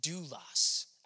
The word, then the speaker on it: doulos: And this word servant, it really comes from the Greek word pronounced doulos. Everyone say doulos.